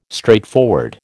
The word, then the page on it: straightforward: American English